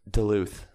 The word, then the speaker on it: Duluth: Is it Duluth? Duluth? – Duluth.